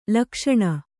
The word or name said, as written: ಲುಕ್ಸಾನ